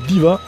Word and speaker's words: Deeva: Et on démarre avec le groupe Diva. Attention, ça ne s'écrit pas Diva comme la Diva, mais ça s'écrit D-E-E-V-A.